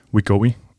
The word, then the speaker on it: Wegovy: Wegovy. Udtaler jeg det rigtigt? Ja, det, det er ret øh, præcist.